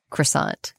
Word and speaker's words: croissant: I'm going to say croissant because that's just the accepted Americanized pronunciation.